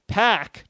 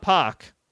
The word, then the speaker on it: Pac: Pac, not Pac. Apparently, I've been pronouncing it wrong.